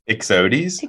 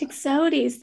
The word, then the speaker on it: Ixodes: I keep saying oxidi. I don't know how to say it. You say it. – No, try it one more time without all us laughing. – No, I'm not doing it again. Tell me the correct way to pronounce. – I think it might just be your Georgia accent. – I'm not saying it again. Let me hear you say it. – Ixodes. – Ixodes, thank you.